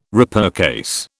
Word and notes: repurchase: British English

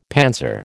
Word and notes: panzer: American English

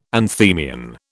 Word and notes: anthemion: British English

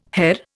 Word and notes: Herr: Indian

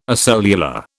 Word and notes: acellular: British English

